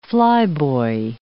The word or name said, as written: flyboy